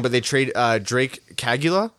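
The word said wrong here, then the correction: Caggiula: Is that right? – Kajula.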